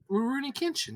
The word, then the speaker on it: Rurouni Kenshin: Rurouni Kenshin? – Rurouni Kenshin, yeah.